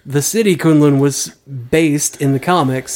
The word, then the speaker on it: Kunlun: Pronounce Kunlun.